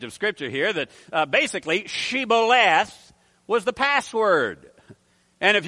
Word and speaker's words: shibboleth: Then they would say to him, say now, shibboleth. But he said, sibboleth, for he could not pronounce it correctly.